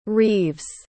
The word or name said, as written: reaves